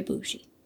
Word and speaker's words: bougie: Very bougie. Emphasis on the boo.